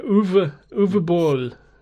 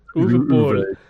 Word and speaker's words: Uwe Boll: Uwe Boll, I think is how you say it. Uwe Boll.